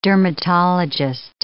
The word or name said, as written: dermatologist